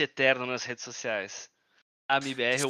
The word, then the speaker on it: MIBR: Vai ser um prazer aqui descobrir um pouquinho mais da, do trabalho da MBR me corrija se eu estiver pronunciando errado. É MIBR que chama mesmo ou tanto faz? A pronúncia tá perfeita.